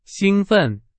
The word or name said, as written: 兴奋